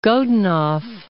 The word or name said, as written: Godunov